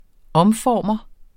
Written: omformer

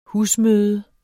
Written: husmøde

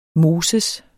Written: Moses

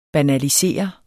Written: banalisere

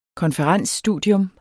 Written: konferensstudium

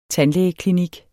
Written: tandlægeklinik

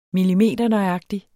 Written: millimeternøjagtig

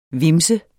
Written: vimse

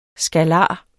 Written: skalar

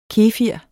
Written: kefir

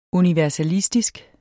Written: universalistisk

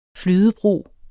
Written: flydebro

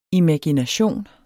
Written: IMAGINATIONEN

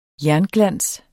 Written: jernglans

